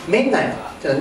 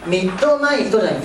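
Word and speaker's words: midnight: ミッナイトというのは 2音だよと。ミッドナイトじゃなくてミッナイト、 2 音で発音しないと数字ないんです。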